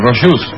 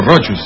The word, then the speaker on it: Rochus: Christoph Rochus, o Rochus, se escribe Rochus.